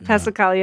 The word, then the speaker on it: Passacaglia: Passacaglia, yeah.